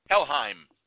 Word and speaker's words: Helheim: Helheim.